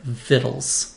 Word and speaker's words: victuals: so we're left with the word spelled like its Latin root, but pronounced like the old French version it was lifted from. – And we get "vittles" spelled V I C T U A L S.